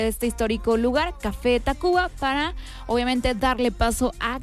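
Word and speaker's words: Tacvba: que también está este cambio en el nombre de la característica U por la V, que de repente no la pronunciamos como debería de ser, sino que directamente decimos Café Tacuba, pero sabemos que se escribe diferente el nombre,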